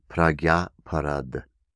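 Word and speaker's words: prajñāparādha: In Sanskrit, it is pronounced pragyaparad, pragyaparad, P-R-A-G-Y-A, and then there's an A next, aparada, A-P-A-R-A-D-H, and then there's an A on the end, but it's silent, as in most Sanskrit pronunciations. Pragyaparad.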